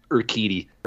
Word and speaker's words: Urquidy: And the Blue Jays will be facing Zach Greinke, Jose uh, Urguati. I forget how you say his name. – It's the, it, Urquidy.